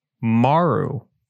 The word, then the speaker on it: Marle: It's pronounced Maru. – Okay. – But it's actually spelled M-A-R-L-E. It's the first female character you get introduced in the game.